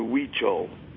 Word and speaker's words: Huichol: Don Jose Matsua, and then the tribe is the Huichol.